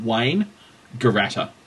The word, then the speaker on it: Wangaratta: When I've taught people from overseas how to pronounce it correctly, I've told them the simplest way to do it is to think of it as a man's name, first name and last name. Wangaratta.